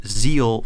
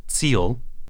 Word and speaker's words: Ziel: German-speaking listeners, of course, know that that's not actually how you pronounce that German word. It's with a hard Z sound, so it's more like Ziel.